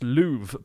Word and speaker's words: love: The word love would have been pronounced louve back in the day because of the way Shakespearean English, like around that kind of era, pronounced certain vowels.